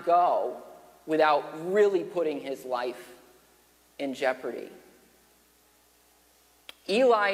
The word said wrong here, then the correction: Ellie: is living in a time and place, or excuse me, Ellie is how his name is pronounced.